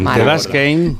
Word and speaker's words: Kane: Kane, muy bien.